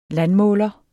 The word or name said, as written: landmåler